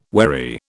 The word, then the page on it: wary: British English